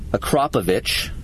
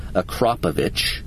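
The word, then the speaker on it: Akrapovič: With the 2017 bike, it comes, you know, obviously with the stock muffler, but with the factory edition, it comes with that slip-on Akrapovich. That's how you say it, people. So if you're wondering how you say it, you can say it five times. Akrapovich.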